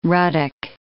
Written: ruddock

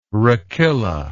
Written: rachilla

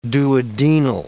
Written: duodenal

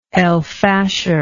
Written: El Fasher

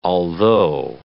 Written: although

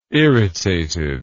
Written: irritated

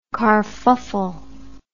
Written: carfuffle

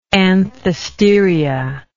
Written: anthesteria

